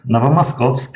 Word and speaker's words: Novomoskovsk: Is that how I pronounce it? – Yes, Novomoskovsk. – Novomoskovsk.